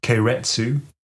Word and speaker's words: keiretsu: So these conglomerates, which are kind of entangled with one another in Japan, are known as keiretsu.